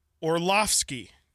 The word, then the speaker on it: Orlovsky: Orlovsky. – Yeah, see, that's hard for me to say. – Orlovsky.